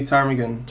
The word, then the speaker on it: ptarmigan: So how do you say ptarmigan? – Just like that. – Okay, so the P is silent.